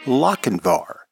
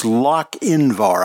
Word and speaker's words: Lochinvar: That was Gunsmoke, and the name of that episode was Lochinvar. And it's Lochinvar. I think I mispronounced it at the beginning of the show.